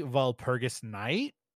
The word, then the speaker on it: Walpurgisnacht: Walpurgisnacht, right? Is that how you say it? – Walpurgisnacht. – Walpurgisnacht. It is actually Walpurgisnacht, which is, I just had it here, an abbreviation of Saint Walpurgisnacht,